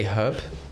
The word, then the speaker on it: herb: Wait, do you say herb? – Yeah, herbs. – Oh, thank God. A lot of people say herbs and they don't pronounce the H.